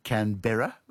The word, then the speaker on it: Canberra: Then in that case, do you say Canberra or Canberra? – I say Canberra. – Yeah, so do I. But the Americans say Canberra for some reason.